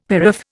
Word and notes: paraph: Indian

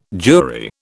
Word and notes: jury: British English

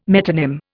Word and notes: metonym: Indian

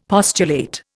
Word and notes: postulate: Indian

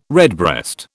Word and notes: redbreast: British English

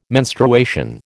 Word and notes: menstruation: American English

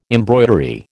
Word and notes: embroidery: American English

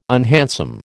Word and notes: unhandsome: American English

